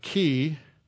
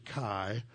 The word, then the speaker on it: chi: And so the letter X that we see is the letter uh, key. Sometimes, if you were in a Greek fraternity or something, you learned to pronounce it chi, but it's key and that was the letter X.